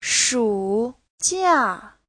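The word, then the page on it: shujia: shu3jia4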